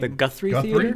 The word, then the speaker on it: Guthrie Theater: If not, we're coming to you live from the, is it, how do you say that theater name? – The Guthrie Theater.